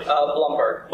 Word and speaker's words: Blumberg: Blumberg. – Blumberg. Why is that hard for me to pronounce? – I don't know why people can't figure that out when there's no E in it. The first part.